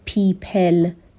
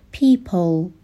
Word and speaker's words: people: نگیم people، صدای ای نیست، در واقع یک صدای مشابه صدای او هستش. people به این صورت باید عمیق تلفظ بشه.